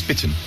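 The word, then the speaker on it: Bitterne: How do you pronounce that? – Bitten. – Is that right? – Yeah. It is, it's Bitten.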